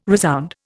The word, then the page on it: resound: Indian